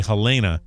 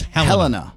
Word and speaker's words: Helena: A lot of people say Helena, but it's Helena.